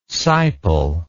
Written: siple

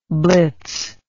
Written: blitzer

